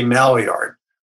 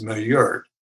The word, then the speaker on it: Maillard: I say Maillard, but it's Maillard.